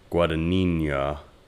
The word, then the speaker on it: Guadagnino: Let's say Guadagnino.